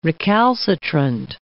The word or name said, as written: recalcitrant